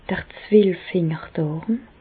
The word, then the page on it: d'r Zwelffingerdàrm: Bas-Rhin; Reichshoffen